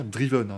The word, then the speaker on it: Driven: Et je tiens à préciser que ça se prononce bien Driven. Hein. Je dis pas ça genre, eh, français. Ça se prononce Driven. To drive, Drove Driven.